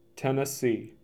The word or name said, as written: Tennessee